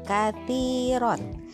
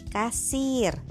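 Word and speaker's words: kathiron (كثير): Misalnya nih, kita baca uh, tulisannya kathir, kaf, sa, ya, ro, kathiron. Artinya itu banyak. Tapi kalau taknya dibaca sa atau sin, bacanya jadi kasir.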